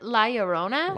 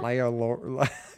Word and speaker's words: La Llorona: uh, I'll tell you guys, it's the curse of La La Nora or whatever. I'm pretty I sure I pronounce it, it La Llorona. – La Llorona.